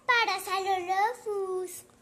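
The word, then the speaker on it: Parasaurolophus: Y se pronuncia Parasalolophus.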